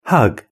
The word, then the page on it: hug: British English